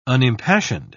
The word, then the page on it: unimpassioned: British English